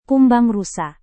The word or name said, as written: kumbang rusa